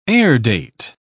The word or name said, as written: airdate